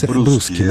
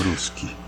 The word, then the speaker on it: Bruschi: Acho que é assim que pronuncia, Bruschi. – Bruschi, né? É um sobrenome italiano. – Isso. Né? Bruschi.